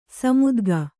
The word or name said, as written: ಸಮುದ್ಗ